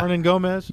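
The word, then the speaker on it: Hernangómez: Is it Herman Gonit? Yeah. – Hernan Gomez? – Yes.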